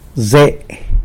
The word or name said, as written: ze